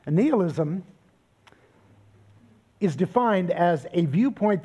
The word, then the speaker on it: nihilism: One of them is nihilism, or nihilism, if you prefer that pronunciation. That's the one that Merriam-Webster shows as the preferred pronunciation. I like nihilism partly, mostly because the word comes from the Latin word nihil, which means nothing.